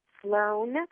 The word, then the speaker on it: Slon: Last name is um, it's Sloan, but it's spelled S L O N. There's no A or E in it. Uh, the number nine dot com. So Cynthia Sloan nine dot com.